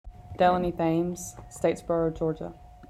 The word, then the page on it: Delanie Thames: DEL-a-knee TH-ames